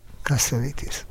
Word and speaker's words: Cassavetes: Tudod, hogy hogy kell Cassavetes nevét kiejteni? – Cassavetes. – Igen, én mindig Cassavetesnek mondtam. – Én is Cassavetesnek mondtam. – Igen, egy amerikai barátnőmtől hallottam. – Ja, kiejtette, és így kell. – Kasszavitis.